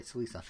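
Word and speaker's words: Celica: Am I saying? Yeah, I am saying that right, Celisa.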